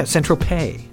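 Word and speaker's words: Saint-Tropez: Yeah, San Tropez. Is that how you do it? – Yeah. – Yeah. Is that French? – It rhymes with George Takei.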